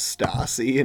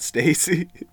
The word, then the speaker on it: Stassi: Can I just say how much I hate that her name is Stassi and not Stacy? – How would you pronounce S-T-A-S-S-I? It's short for Nastasia. Ugh.